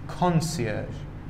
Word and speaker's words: concierge: مورد بعدی تلفظ این کلمه یکم خاص هست. concierge. این کلمه فرانسوی هست و تلفظ خاصش هم به همین خاطر هست. concierge.